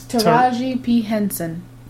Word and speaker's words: Taraji P. Henson: Taraji P. Henson.